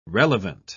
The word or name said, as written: relevant